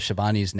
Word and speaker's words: Schiavone: Did you notice they had trouble with Schiavone's name at first and Gordon Solie had trouble the whole time? – No, I didn't. – Yeah. He couldn't pronounce Schiavone right. He called him Schiavone.